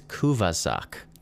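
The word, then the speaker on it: kuvaszok: Oh, and a fun fact, the plural, which we may have misused Kuvas, the plural already, but the plural is Kuvazak.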